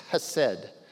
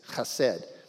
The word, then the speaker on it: chesed: It's chesed. The H has kind of a KH sound to it, but it's chesed.